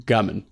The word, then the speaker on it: Gbamin: John Philip, either the G is silent Gbamin or the B is silent, but we'll call him Gbamin.